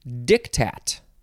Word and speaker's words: diktat: Diktat. Actually, you emphasize the second syllable, so it's diktat.